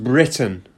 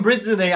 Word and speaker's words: Brittany: Pronounced Britain. – No, that's us. This is them, Brittany.